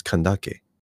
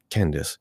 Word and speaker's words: Kandake: It's Kandake. And this Candace is the Latinized version of it. So like, for people who are learning that culture from the West to pronounce it, they pronounce it Candace.